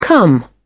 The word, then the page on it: come down: British English